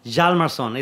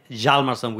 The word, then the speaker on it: Hjalmarsson: Hjalmarsson. It's HJ. It's a hidden H. So it's actually Hjalmarsson with J, Hjalmarsson.